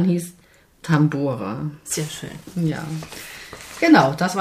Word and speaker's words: Tambora: Ja, der heißt Tambora.